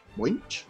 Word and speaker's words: Voynich: ¿Lo pronuncié bien? Voynich. Voynich. Ok.